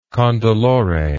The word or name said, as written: condolore